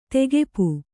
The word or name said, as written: ತೆಗೆಪು